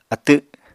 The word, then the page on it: atw: Apatani